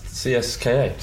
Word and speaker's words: CSKA: You're not bothered by the way I pronounced CSKA. – That is how you say it.